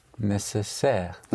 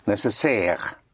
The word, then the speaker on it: nécessaire: So instead of in spelling, A-R-Y is spelled A-I-R-E. So necessary would be? – Nécessaire. – Nécessaire.